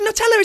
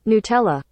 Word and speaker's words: Nutella: Yeah, it's Nutella.